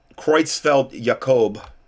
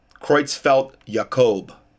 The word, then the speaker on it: Creutzfeldt Jakob: Um, Creutzfeldt Jakob is, I believe, the proper pronunciation. Disease, or CJD. Creutzfeldt Jakob.